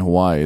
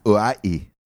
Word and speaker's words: Hawaii: So Washington and Hawaii. It's in Washington. – I think you pronounced that wrong. – Hawaii. – No, it's Hawaii. Hawaii.